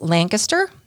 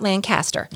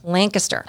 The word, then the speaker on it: Lancaster: And it's just west of Lancaster, not Lancaster. Lancaster.